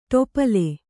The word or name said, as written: ಟೋಪಲಿ